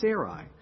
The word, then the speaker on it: Sarai: Did he mispronounce it? No, now it's Sarai.